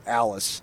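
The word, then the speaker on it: Alliss: Someone in the crowd yelled, nice putt, Alice. Because that's how you pronounced his last name, L-L-I-S.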